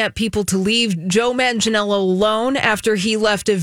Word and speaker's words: Manganiello: I'm pretty sure that's right. Does anyone know like an official pronunciation? – I think you did. – Manganiello. – I always trip over his name, but I think that's right.